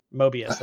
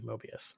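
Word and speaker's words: Möbius: Mobius, that's right, the B, Mobius. – Right. Mobius and Mobius. – It's like the vampire without the R, Mobius.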